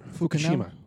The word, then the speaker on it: Fukushima: Her name is Rayla Fushinama? I think that's how you say it. Fushinama? – Rayla Fukushima. – Fukushima? Yeah.